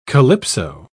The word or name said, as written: calypso